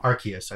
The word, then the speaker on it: Arceus: I thought it was a hard C. I don't know. – Arceus. I think it's Arceus. Yeah. I've heard both, but Arceus. Okay. Yeah. I think in the Pokemon Direct they said Arceus. Anyway,